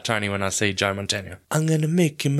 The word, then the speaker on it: Mantegna: Say it phonetically. Mantegna. It's Montaigne.